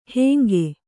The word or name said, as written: ಹಾಂಗೆ